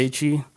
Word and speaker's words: Gaethje: Yeah, Gaethje.